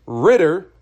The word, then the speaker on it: Ridder: It is Ridder. The quarterback for Atlanta is Ridder, not Ryder. I said that wrong.